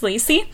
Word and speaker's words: Lacey: Lassie. I've gotten Lassie. I've been called Lucy, although that's wrong. I've been called Lazy, but that's because some people have accents and it's a little harder for them to pronounce Lacey.